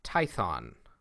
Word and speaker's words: Tython: Say, take him to the planet Tython. There you will find... – Which rhymes with Python.